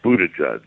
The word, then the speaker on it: Buttigieg: You pronounce it, it's a Maltese name. – Yeah. – It's Pete Buttigieg.